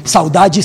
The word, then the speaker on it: saudade: Forma de decir saudade, una palabra que en Brasil conocemos mucho, saudade, saudade, es un término portugués que así se pronuncia en el archipiélago.